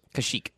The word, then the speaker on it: Kashyyyk: Yeah, they had to get Chewbacca back for Keshek. – Yep. – For a life day. – Kashyyyk. – Yeah, Kashyyyk. Thank you for my...